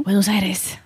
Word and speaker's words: Buenos Aires: Alors que j'effectue un vol à destination de Buenos Aires, tu me dis si je le prononce mal, probablement. – Buenos Aires.